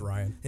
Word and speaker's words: Rian: And since Rian Johnson was, or Ryan, I don't even say his name. I think it's Ryan. – It is Ryan. I always mess up his name.